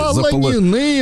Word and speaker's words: заполонены: И, и второе, если глобально говорить, вот эта история с тем, что ну, наши соцсети заполонены, я так надеюсь, правильно поставил училка в ударение? Заполонены Запол... вы мои, заполонены. Вот.